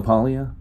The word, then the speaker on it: Paglia: Camille Paglia. Some people pronounce it like Paglia, but I think the G is silent.